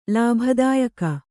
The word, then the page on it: ಲಾಭದಾಯಕ: Kannada